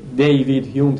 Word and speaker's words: دیوید هیوم: همون داوید هیومه که دیوید هیوم تلفظ میشه انگلیسیش و شاید اصلش هم داوود باشه، دیوید هیوم تلفظ انگلیسیش هست.